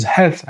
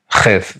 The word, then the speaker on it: Heth: So the correct pronunciation of the letter Heth is Heth and not Cheth, which is definitely a mistake and needs to be corrected.